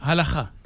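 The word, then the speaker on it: Halakha (הלכה): Halakha.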